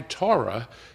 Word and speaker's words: Torah: In the Torah, is that how you pronounce it? – Torah.